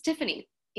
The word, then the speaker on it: Tiffany: I get Tapani or Tapani. I don't think that it's that difficult. Like it's phonetically, like it's just Tiffany, you know? – Yeah.